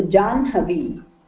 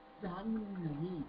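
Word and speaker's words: Janhavi (जाह्नवी): Janhavi, na has to be pronounced first, okay? So Janhavi. – Janhavi.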